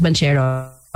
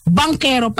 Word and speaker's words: Banchero: This whole time, he never corrected people calling him Chris Banchero. Banquero pala yung pronunciation. Kakakorek na lang this year.